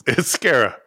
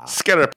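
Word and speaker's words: Sara: Is it pronounced Sarah? Like, is that right? – It's Scara. – Sarah. – Scara Picelli.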